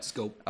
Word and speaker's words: Schoop: We've got Jonathan Schoop. We've got Scope. – Pardon me. I don't know why I pronounced that as Schoop.